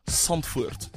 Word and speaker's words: Zandvoort: Zandvoort, apparently is how you say it.